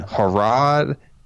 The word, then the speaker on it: Harad: Okay, so what I mean by that is in Harad and in Khan,